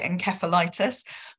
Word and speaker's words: encephalitis: I think you say encephalitis? How do you pronounce the word? I'm sorry, because my English is a little rusty. – No, it's perfect. So in the UK, we like to be different, so we call it encephalitis, but in North America, okay.